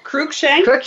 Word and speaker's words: Cruickshank: Cruickshank.